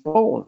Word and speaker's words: Borgen: You must have been watching a lot of Danish Borgen, which is, of course, as you know, is how we pronounce it, right? – Yeah.